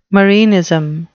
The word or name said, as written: marinism